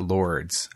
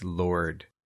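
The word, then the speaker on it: Lourdes: The village of Lord, and it's not Lords, I figured out today. It's Lord. I've been pronouncing it Lords all my life. – Everyone says Lords.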